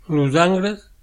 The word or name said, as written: Angles